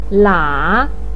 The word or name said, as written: la3